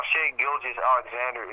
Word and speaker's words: Shai Gilgeous-Alexander: See, I'm not even pronouncing his first name right. Oh my god, his name is just giving me aneurysm. Ty Gilgis Alexander. Shay Gilgis Alexander. Shai Gilgeous-Alexander has like everything that Nubakina does the defense and the playmaking ability, but not so much the offense.